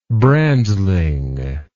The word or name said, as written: brandling